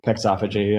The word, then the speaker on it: pexophagy: pexophagy, yeah.